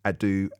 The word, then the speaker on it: Adoo: You can also text me 85058, and you can find me on socials, on social media, at Edward Adu, and my surname is spelled ADOO.